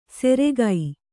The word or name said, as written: ಸೆರೆಗೈ